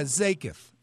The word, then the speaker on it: Zakath: And having said that, when I read Zakath, is that the way you pronounce his name? – Yeah, Zakath, Zay, like Z-A-Y.